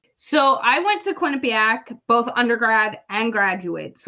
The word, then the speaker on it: Quinnipiac: Am I saying that right? Quinnipiac. – You are. You said it perfectly.